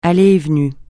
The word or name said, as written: allées et venues